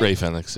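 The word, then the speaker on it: Rey Fénix: When you have Kyle O'Reilly tap out Ray Fenix? Did I say it right? – Ray Fenix, yeah.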